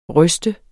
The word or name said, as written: ryste